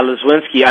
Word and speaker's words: Lozwinski: How do you say her last name? – I think it's Lozwinski. – Yeah, Lozwinski. I mean, she just, like, came out of nowhere.